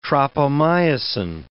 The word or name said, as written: tropomyosin